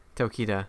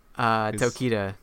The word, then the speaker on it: Tokita: Tokita. Yeah, Mr. Takis. Mr. Takis. – Mr. Takis, okay. – Tokita, yeah.